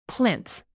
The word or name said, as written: plinth